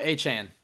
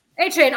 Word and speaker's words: Achane: Achane. – Achane.